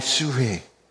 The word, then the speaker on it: psyche: In the New Testament, the Greek word is suhe. And it's spelled P-S-Y-C-H-E, like psych.